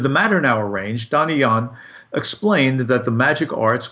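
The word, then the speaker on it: Illán: Don Ilan, or Ian, if you give it the true Spanish pronunciation or Castellano pronunciation,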